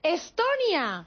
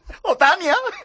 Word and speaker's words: Estonia: E-S-T-O. – Ah, N-I-A. – Estonia. – Octania. – ¡Otania!